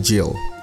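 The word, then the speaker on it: Gill: Oh, sorry, berarti bacanya bukan Gil Hassan, bacanya The Jill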